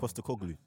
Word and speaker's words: Postecoglou: Postecoglou.